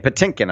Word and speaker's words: Patinkin: Then Mandy Patinkin said he had no idea Oak wasn't on board with this and refused to step into the role after all. Patinkin said the producers misled... – Patinkin.